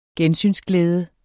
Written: gensynsglæde

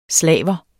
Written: SLAVERE

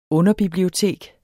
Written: underbibliotek

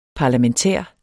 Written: parlamentær

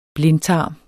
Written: blindtarm

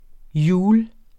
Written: hjul

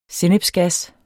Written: sennepsgas

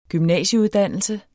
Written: gymnasieuddannelse

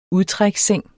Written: udtræksseng

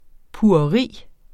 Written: pugeri

